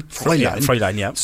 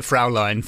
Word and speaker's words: Fräulein: Fräulein. – So I do pronounce it Fraulein for the entire book.